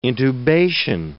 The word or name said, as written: intubation